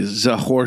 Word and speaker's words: Zachor: And did I pronounce that correctly, Zachor, Shoah? – You did great. You did fantastic.